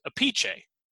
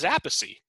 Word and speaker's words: Appice: Because it was no longer in there, but I could have sworn that this is where I got this, that in Italian, the name would be pronounced Appice. Okay. Which it makes sense. And that Carmine and Vinnie both pronounced it Appice professionally.